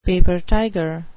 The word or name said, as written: paper tiger